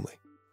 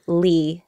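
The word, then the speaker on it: Le: So the traditional pronunciation of the last name L-E mm-hmm. is Leigh, but I'm used to calling myself Lee, L-E, yeah. because growing up, if you come from a Vietnamese American family or like an immigrant family, you have like an American way of saying your name right. – and a Vietnamese way of saying your name. So yeah, I just grew up feeling like Lee was my last name.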